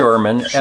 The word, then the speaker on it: Schuurman: Kathleen Schuurman, S-C-H-U-U-R-M-A-N.